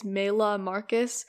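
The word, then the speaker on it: Maylah Marcus: my Apple Music username is Mayla Marcus. My name is spelled M-A-Y-L-A-H, and then Marcus is spelled M-A-R-C-U-S.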